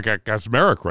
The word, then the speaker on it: Kacsmaryk: And I even got Kacsmaryk right, too.